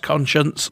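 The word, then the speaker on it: Konchuntz: Pretty sure it's pronounced Conscience, but it's spelled K-O-N-C-H. UNTZ.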